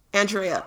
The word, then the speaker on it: Andrea: Andrea. I say I say Andrea, but I'll answer to anything.